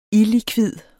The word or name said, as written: illikvid